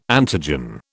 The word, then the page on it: antigen: British English